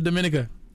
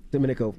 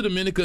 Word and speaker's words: Dominica: Tales of Dominica. – Dominico. Yeah. What's that about? – And he's from there and didn't even pronounce it right.